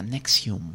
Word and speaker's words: Nxivm: Se escribe Nxivm y se pronuncia Nexium.